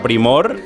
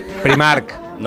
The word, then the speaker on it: Primor: Sí, si vas a Primor... – Primark. – No, Primor. – Ah, Primor. Sea que pronuncia Primor, bien.